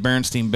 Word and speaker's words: Berenstain: No, but it's this idea that, like, if you growing up and you read the Berenstain Bears, you remember it probably spelled S-T-E-I-N, Berenstain Bears. And today, if you look at it, it's spelled S-T-A-I-N. And people have a very firm memory that it was Steen and not Stain.